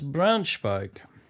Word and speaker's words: Braunschweig: That's Braunschweig.